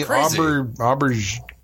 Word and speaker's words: Auberjonois: Rene Auberjonois. It's a French-sounding name. – Rene. Just say it like French. – Auberjonois.